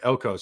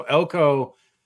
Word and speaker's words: Elko: The guy, Mike Elkness, how do you say his name? The coach for Duke? – Elko. – So Elko, he said on his coach's show last night that he could go potentially.